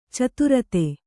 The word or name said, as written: ಚತುರ್ಥಿ